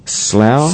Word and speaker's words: Slough: how do you pronounce it, S-L-O-U-G-H, Slough? – Slough, yes, Slough, lovely place.